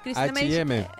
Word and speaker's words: H&M: H&M.